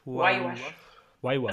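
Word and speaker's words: Huayhuash: Einmal den, ähm, ich, ich kann es nicht aussprechen, Huayash? – Why wash. Also im Prinzip Why das englische Warum waschen? Why wash? – Why, äh, why wash? Ja, das ist äh, recht einfach zu merken.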